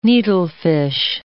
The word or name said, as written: needlefish